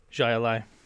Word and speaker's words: Jai Alai: You see, the AI is pronounced exactly the same at the end. Jai Alai.